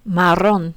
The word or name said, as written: marron